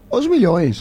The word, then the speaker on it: Os Milhões: Claro, es verdad, por eso nos dice Rodolfo, por eso el Fla Vasco se le dice el clásico de Os Milhões. No sé si está bien pronunciado. – Os Milhões. – ¿Cómo? – Sí. Os Milhões.